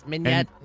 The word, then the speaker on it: mignonette: A 630, oysters on the half shell, mignonette. – Oh, yes. – Am I saying that right? – Yes, mignonette.